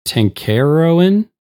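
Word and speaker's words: Tancharoen: Director was Kevin, and I apologize if I butcher the last name, Tenkarowin. – That's how I would have said it.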